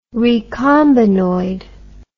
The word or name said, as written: recombinoid